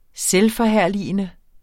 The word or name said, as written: selvforherligende